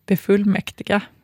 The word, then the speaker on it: befullmäktiga: att då det här med B-prefixet kan få betoning. Ett exempel då som de har, och nu ska vi se om jag kan säga det här, B-fullmäktiga, alltså som vi vill då säga befullmäktiga.